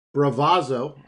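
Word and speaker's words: Bravazo: Bravazzo, I pronounced that right for our Italian friend. – Yes.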